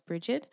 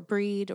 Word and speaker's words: Brigid: and if I were going to, so Imbolc is all about the goddess Brigid, and she's called Brigid or Breed or Bridget. She's got lots of ways of pronouncing her name.